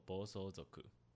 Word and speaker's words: 暴走族: 暴走族的日文念作 “bosozoku”，主要发基于一九六零七零年代左右，在一九九零两千年代左右呢，渐渐的式微。